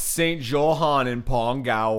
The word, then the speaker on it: St. Johann im Pongau: Yeah, it's Saint Johann in Pongau.